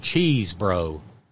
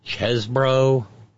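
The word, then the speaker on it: Chesebro: I like how you say his name, Cheese Bro. Why, that's C-H-E-S-E-B-R-O. I guess he's trying to say now that his name's Chesbro.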